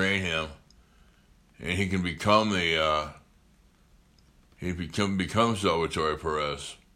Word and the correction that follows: Salvador Perez: Salvador Perez.